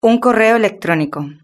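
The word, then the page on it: un correo electronico: Spanish (Spain)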